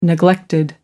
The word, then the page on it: neglected: American English